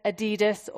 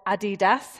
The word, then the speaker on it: Adidas: And so Adolf, known to his friends as Adi, renamed his business Adidas, or Adidas, how you like to pronounce it, whilst Rudolf's part of the business became known as Puma.